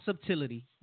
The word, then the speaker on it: subtlety: Not subtlety, all right? Any brothers listening, it's subtlety, okay? – Right. – I hate when people pronounce that thing wrong.